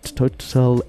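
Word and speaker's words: TotalEnergies: It's great to have you in the house and to be talking about what's hot and happening down at Total Energies. Well, see, this is the thing. People, South Africans call it Total, but if you're doing the French pronunciation, it's Total.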